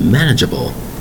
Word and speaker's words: manageable: Today's word is manageable. Manageable. Four distinct syllables, spelled M-A-N-A-G-E-A-B-L-E.